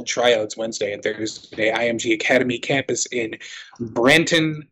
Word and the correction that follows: Bradenton: Florida. Mm-hmm. Probably not saying that right. You would know better than I would. – Bradenton. – The tryout. What is it? – Bradenton. – Bradenton. There you go.